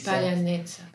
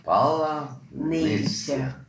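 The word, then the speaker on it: Palanitsa (Паляниця): Dreht den Spieß um und versucht uns die richtige Aussprache für Brot beizubringen. Oder vielmehr ukrainisches Sauerteigbrot. – Palanitsa. – Palanitsa.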